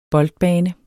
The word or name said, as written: boldbane